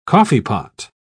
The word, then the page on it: coffeepot: British English